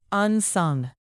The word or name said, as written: unsung